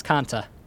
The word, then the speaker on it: Kanta: Then you also have a young boy who's in the neighborhood, Kanto, the Kanto region. – close. You're one letter off. It's Kanta.